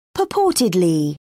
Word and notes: purportedly: British English

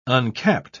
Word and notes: uncapped: British English

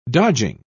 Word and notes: dodging: British English

